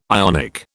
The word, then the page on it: Ionic: British English